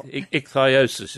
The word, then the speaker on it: ichthyosis: Ichthyosis, is that what it is? – It's ichthyosis, yes, yeah.